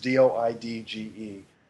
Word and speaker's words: Doidge: I've never heard it pronounced, but I believe it's Norman Doidge. It's Doidge.